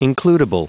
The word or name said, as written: includible